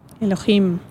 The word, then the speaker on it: Elohim: O Elohim, Elohim. – Si se quiere ser más, más, fiel a la pronunciación original.